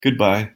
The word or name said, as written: goodbye